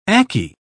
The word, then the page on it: akee: British English